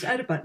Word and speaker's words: Oedipa: and it's spelled O-E-D. So unless we think he's calling her Ode, he is, I believe it's pronounced Oedipa. Now, I actually want to come back to O-E-D because that's a very Pynchonesque joke and there's a reason why her name is Ode.